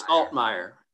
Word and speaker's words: Altmire: Did I say it right, or was it Altmire? – It's Altmire.